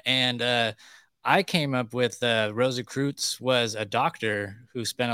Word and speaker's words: Rosicrucians: The Rosicrucians.